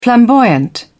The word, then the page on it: flamboyant: British English